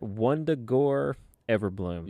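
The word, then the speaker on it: Wundagore Everbloom: Let me see if I can say this right. Wondagore Everbloom. – Yeah. – There we go.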